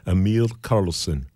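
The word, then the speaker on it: Emil Karlsen: His name is spelled E-M-I-L, first name, Emil, and then K-A-R-L-S-E-N, Emil Karlsson.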